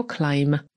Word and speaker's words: climb: No i przypominam, że climb piszemy przez B, ale tego B nie wymawiamy, więc wymawiamy to słowo jako climb, M na końcu.